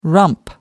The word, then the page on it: rump: American English